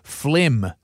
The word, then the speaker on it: phlegm: Phlegm. Jeez, that whole thing is just a shambles. – That's a P-H-L-E-G-U-M? – P-H-L-E-G-M. – Phlegm.